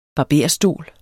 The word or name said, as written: barberstol